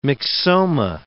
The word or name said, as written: myxoma